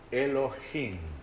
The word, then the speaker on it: Elohim: Aí diz Elohim, se lê de direita para esquerda, mas se escribe assim, Elohim. Essa é a letra Aleph, a letra E, a letra L com este ponto, Elohim.